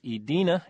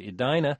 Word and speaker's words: Edina: In the corrections department, Virginia Green, who hears only a game on WYPR in Baltimore, wrote to point out that I had mispronounced the Minnesota city from which former U.S. Women's Open champion Hillary Lunke hails as Edina instead of Edina.